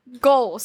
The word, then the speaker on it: Gohl: No, it's Ghoul. Or no, Gohl. Sorry, Gohl. – Gohl. Billy Gohl. Oh, well. The sheep fan. – Yeah. So his name is spelled G-O-H-L.